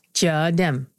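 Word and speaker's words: Chợ Đêm: So Cho Dem.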